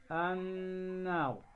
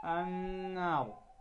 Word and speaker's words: النار: أحبتي في الله، كنا قد تكلمنا في المجلس السابق عن حكم النون المشددة وحكم الميم المشددة، وقلنا أن النون والميم المشددتان عند النطق بهما لا بد أن يظهر أو تظهر الغنة بمقدار حركتين، فإذا نطقنا كلمة النار في كتاب الله، هذه النون نون مشددة، نون مشددة، فلا بد إذن أن نظهر الغنة بمقدار حركتين لا أكثر، فنقول النار، فنقول النار.